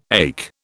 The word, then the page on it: ache: British English